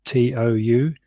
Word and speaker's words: Huatou: specifically such meditation methods as koans or hua tuo? My apologies for mispronunciation. It's Huatou, where there is a strong emphasis on the cultivation of doubt.